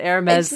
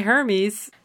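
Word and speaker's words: Hermès: You know that Hermes scarf is a thing. – I hope it was right. I hope it was right. – And you said it right. You didn't say Hermes.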